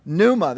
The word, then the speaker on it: pneuma: Pneuma, that's exactly right, with a silent P at the beginning. P N E U M A would be how we would kind of uh, transliterate it into English.